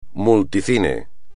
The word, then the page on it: multicine: espagnol